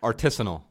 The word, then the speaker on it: artisanal: Of artesian firewood. – Artisanal. – Artisanal, that's what it is. Yeah. Am I not saying that right? – It's artisanal firewood, yeah.